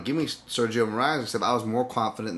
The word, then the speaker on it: Moraes: Moraes looked way better. Is that how you say his name? I don't. – Yep, yep.